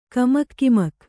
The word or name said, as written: ಕಮಕ್ ಕಿಮಕ್